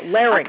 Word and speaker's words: larynx: No, larynx. – I pronounce it. – You're pronouncing it like three syllables. – It's L-A-R-Y-N-X.